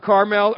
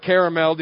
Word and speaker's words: Carmel: Mount Carmel or Caramel, depending on where you come from.